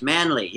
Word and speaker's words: Manley: Right, Manley, yes.